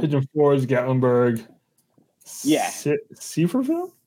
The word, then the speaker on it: Sevierville: I think it's pronounced Sevierville, uh, and it's called Parkway, and it's uh, you know it's you know bigger bigger highway in some places than others.